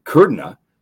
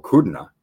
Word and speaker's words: Kudrna: Ben Kudrna, I believe that's right, or Kudrna.